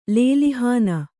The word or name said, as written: ಲೇಲಿಹಾನ